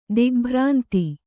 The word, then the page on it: ದಿಗ್ಭ್ರಾಂತಿ: digbhrānti